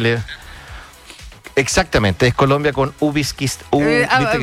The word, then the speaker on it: Uzbekistán: Y hoy Cristiano Ronaldo con Uzbekistán. No, lo dije mal el país, ¿no? – Uzbekistán. – Uzbekistán.